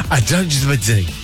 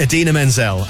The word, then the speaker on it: Idina Menzel: Adele Dazeem. You pronounce it Idina Menzel.